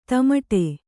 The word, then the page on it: ದಮಡೆ: damaḍe